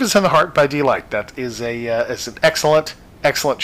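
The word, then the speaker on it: Deee-Lite: It's, it was definitely a one-hit wonder by a group called Deee Lite. D-E-E-E. It has three E's. – Deee Lite. – Deee Lite. – Because you can't spell it just delight. That would be wrong.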